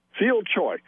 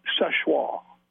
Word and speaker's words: Seulchoix: Seulchoix. – And that is, thank you for playing. It's actually pronounced Sechoix.